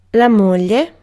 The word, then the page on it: moglie: [la ˈmɔ.ʎe]